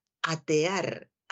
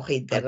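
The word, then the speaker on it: hatear: he visto la palabra atear, atear con H y como suena, nada de pronunciar como hater, nada que ver.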